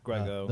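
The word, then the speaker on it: Gergő: And there's I always say his name wrong, Gorgio Borlai. – Yeah, Grego.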